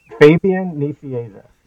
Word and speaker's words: Fabian Nicieza: Fabian Nicieza.